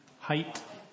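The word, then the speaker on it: Haidt: So, Smartphone Addiction and Gen Alpha by Jonathan, how do you say his name? Height.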